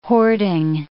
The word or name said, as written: hoarding